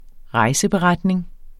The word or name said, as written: rejseberetninger